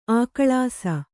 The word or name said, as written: ಅಗಳಿಸು